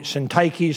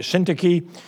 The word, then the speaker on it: Syntyche: Now, I never did know how to pronounce these two people, okay? Euodia, some call it Euodia, some call it Syntyche, some call it Syntyche. One preacher couldn't pronounce them. He called them Odious and Stinky.